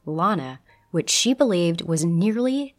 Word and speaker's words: Lana: Quote, "Her name was Lana, as in la-di-da," she would say while correcting people. "It offended her ears to hear it pronounced any other way, and it's still jarring for me to hear it pronounced with a flat A, as in land," unquote.